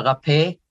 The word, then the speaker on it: Raphael: No, Raphael. Healer God, Raphael, Raphael. As a fa and a pa in Hebrew is the same.